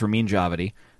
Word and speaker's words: Ramin Djawadi: Dramatic score for a series, Game of Thrones, Ramin Djawadi. I said it right that time.